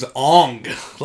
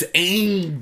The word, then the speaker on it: Aang: His name's Aang. Like, you have three seasons where they say his name. – Yeah. – It's Aang, dog.